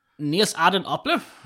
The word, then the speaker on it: Nils Arden Oplev: Låt höra, hur uttalar vi det här på danska nu? – Nils Arden Oplev. – Ja, där satt den som ett smäck.